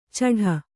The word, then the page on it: ಚಢ: caḍha